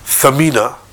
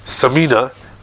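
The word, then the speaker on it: thamina (ثمينة): So if somebody says thamina with a tha, it means very expensive, very valuable. If he says samina, very fat.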